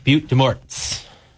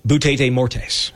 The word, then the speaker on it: Butte des Morts: And, you know, having taken Spanish, I see it's spelled B-U-T-T-E-D-E-S-M-O-R-T-S. – Yeah. – I called it Butte des Morts. – Butte des Morts. It's Butte des Morts.